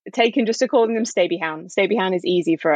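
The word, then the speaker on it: Stabyhoun: Well, in theory, it's supposed to be pronounced the Dutch way, which is Stabby Hound,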